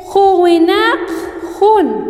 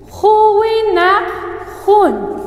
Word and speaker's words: Huwinak Hun: Se dice Huwinak Hun. Huwinak Hun.